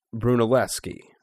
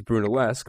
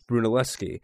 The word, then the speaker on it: Brunelleschi: I know I'm going to do it, but Filippo Brunelleschi, I believe you pronounce his name, although it looks like it's something else. It looks like it's Brunelleschi, but I believe it's Brunelleschi, the man who pretty much designed the first dome.